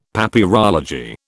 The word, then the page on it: Papyrology: British English